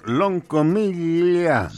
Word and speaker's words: Loncomilla: Vamos a ir inmediatamente con nuestro corresponsal que tenemos por allá en el pueblito de San Javier de Loncomilla. Es con doble L, así que tendría que ser Loncomilla.